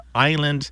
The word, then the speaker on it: island: The S is not pronounced in French. Therefore, we don't pronounce it in this word island. So I'm going to recommend a book. It's by Erica Okrent, O-K-R-E-N-T.